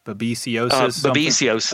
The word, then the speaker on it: babesiosis: I mean, obviously it referenced a few different diseases, but the main one was, how do you pronounce it? Babesiosis? – Babesiosis. Okay. Yeah, babesia, that's huge.